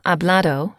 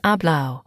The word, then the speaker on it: hablado: For example, hablado equals spoken. Sometimes the Spanish do not pronounce the auto, so hablado would be said as hablao.